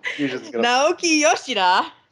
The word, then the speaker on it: Naoki Yoshida: So next we have the interview with Nokia Shida. I totally butchered it. Nokia what? – He's like, Shida. – Naoki Yoshida.